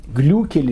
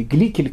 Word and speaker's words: Глюкель: Помните, мы с вами говорили про э, такую необычную женщину, которую звали Глюкель или Гликель, как э, разные произношения, из Гамельна.